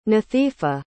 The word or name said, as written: nathifa